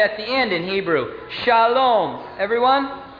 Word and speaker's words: Shalom: You see, in English, whenever we have an unaccented syllable like this one, and the accent is here, we shorten it and we say Shalom. Shalom, you all. Shalom. And you can always tell in the English speaker in Israel. We have to keep it long.